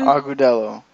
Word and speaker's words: Agudelo: Juan Agudelo. How do you say his last name? – Agudelo.